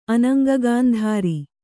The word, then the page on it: ಅನಂಗಗಾಂಧಾರಿ: anaŋgagāndhāri